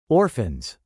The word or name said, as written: orphans